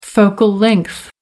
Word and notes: focal length: American English

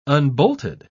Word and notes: unbolted: British English

